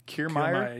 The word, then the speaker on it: Kiermaier: They had Kevin Kiermaier. – Kiermaier, yeah.